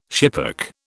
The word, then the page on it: schipperke: British English